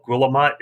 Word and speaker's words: Guillemot: It's spelled Guillemot. It's French, pronounced Guillemot.